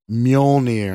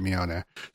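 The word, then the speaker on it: Mjölnir: Mjolnir. – Mjolnir? Mjolnir. I never ever pronounce it right. Mjolnir. So it's fjord. – Yeah, okay, that makes sense. – So it's like that, but it's Mjolnir.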